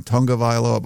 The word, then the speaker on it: Tagovailoa: They changed the pronunciation of his name. It used to be Tagovailoa, and now they're saying Tungavailoa, but whatever.